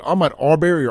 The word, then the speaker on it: Ahmaud Arbery: Am I saying his name right? Is it Ahmaud Arbery or Ahmaud? – Ahmaud Arbery.